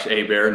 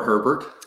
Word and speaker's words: Hebert: So as you said, I'm Josh Hebert, not Hebert or Herbert.